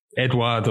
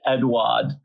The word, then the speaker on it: Edouard: Edward, or however you pronounce it. – Edouard.